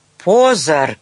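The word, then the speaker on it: Pozor: «Позар!» — крикнул Иржи с ударением на первом слоге. Таня прыснула, хоть и знала, что по-чешски это означает «внимание», и зашлась неудержимым смехом.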